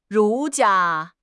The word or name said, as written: 儒家